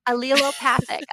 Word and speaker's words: allelopathic: So you think if you learned maybe in biology about alleles, right, when you talk about cells and things like that. So allelopathic. So put allele and opathic together.